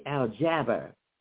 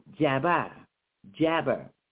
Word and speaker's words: Al-Jabbar: So, and out, and was, they was giving me the pronunciation in the Arabic to say Al-Jabbar. Carry those two B's, Jabbar, Jabbar. You got two of them in there.